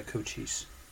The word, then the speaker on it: Cochise: Yeah, Cochise.